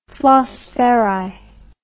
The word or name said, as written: flosferri